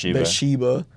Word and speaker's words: Bathsheba: it's got to be the Bathsheba. That's how you say it? – Yeah. – Bathsheba.